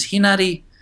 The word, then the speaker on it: Henare: I usually you can get to that through my uh, page, which is Henare Hona O'Brien.